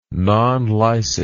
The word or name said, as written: non licet